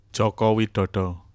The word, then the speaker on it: Joko Widodo: Joko Widodo.